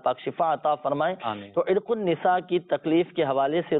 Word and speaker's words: عرق النساء: عرق النساء صحیح تلفظ ہے، عرق النساء،